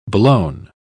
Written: blown

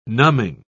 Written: numbing

